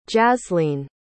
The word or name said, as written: jasleen